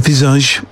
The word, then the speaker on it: Visage: That's how you say it, Visage, apparently.